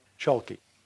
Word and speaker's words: Chalke: The author, it's a partnership, Steve Chalky, that's spelled capital C-H-A-L-K-E, like chalk with an E on it, Chalky, and Alan Mann, M-A-N-N.